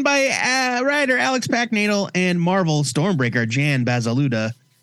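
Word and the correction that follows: Bazaldua: No, I'm sorry. Basaldúa.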